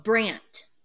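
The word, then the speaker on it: Brandt: It's B-R-A-N-D-T. – Oh, Brant. – Brant. For a second, I was like, Brand, is this like Goonies? Brant. Like the kid in Goonies?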